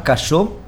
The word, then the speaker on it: Casaux: Pues ella es Diana Casó, la invitada argentina, escritora de asuntos científicos. Diana se casó, se escribe Casaux, es un apellido francés, tiene antecedentes franceses en su familia.